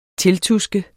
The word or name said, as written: tiltuske